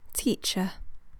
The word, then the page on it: teacher: British English